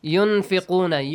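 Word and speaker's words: Yunfiquna (ينفقون): Yunfiquna. – Yun. Nun shouldn't be pronounced clearly. We should hide the sound of nun.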